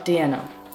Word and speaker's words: Diena: Do I pronounce it Diena or Diena? – Diena. – Diena.